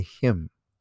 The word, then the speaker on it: Hm: How much of an individual was a hymn? Several facts have come to light from looking at the tomb's biography from Kemet. Hymn pronounced H-E-M were normally married and had families.